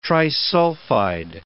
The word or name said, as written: trisulfide